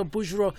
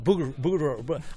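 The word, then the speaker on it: Bouguereau: I always pronounce his name wrong. And I said, you like, there's these painters. I just went to the museum and I saw Bougereau. And then he corrected, Bougereau.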